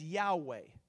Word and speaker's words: Yahweh: And they didn't like to utter the name, and so we don't know exactly the pronunciation, but the Hebrews, we believe, would pronounce it as Yahweh, or what we would call it in English, Jehovah.